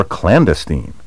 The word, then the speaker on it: clandestine: Clandestine is sometimes pronounced clandestine, clandestine, clandestine, or clandestine. You should avoid all these recent variants. The traditional and preferred pronunciation is clandestine. Word 7. Tirade. T-I-R-A-D-E.